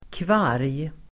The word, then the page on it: kvarg: [kvar:j el. -g]